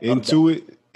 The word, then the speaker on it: Intuit: Intuit.